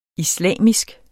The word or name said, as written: islamisk